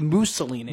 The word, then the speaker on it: Mussolini: He wants to say it properly just in case his family is listening so he doesn't disrespect them. – He doesn't want to get those emails from, like, it's actually phonetically Mussolini.